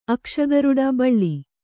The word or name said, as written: ಅಕ್ಷಗರುಡ ಬಳ್ಳಿ